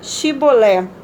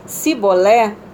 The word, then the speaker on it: Shibolé: se declarava não lhe ordenava, então dize Shibolé. Se a pessoa dissesse Sibolé sem conseguir pronunciar corretamente a palavra, prendiam essa pessoa e a matavam no lugar de passagem do Jordão.